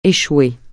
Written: échouer

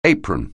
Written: apron

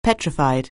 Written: petrified

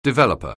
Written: developer

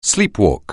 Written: sleepwalk

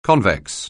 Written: convex

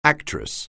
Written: actress

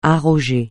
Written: arroger

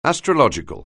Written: astrological

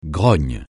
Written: grogne

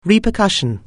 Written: repercussion

